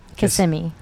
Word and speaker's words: Kissimmee: Uh, that's in uh, how do you say, it, honey? – Kissimmee. There you go.